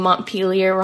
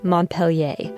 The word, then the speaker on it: Montpelier: With apologies to the French language, our state capital is not pronounced Montpelier. Samantha now says Montpelier because that's how I say it, Montpelier.